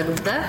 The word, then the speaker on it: Lute: Io la inglesizzavo, ma in realtà si legge Lute.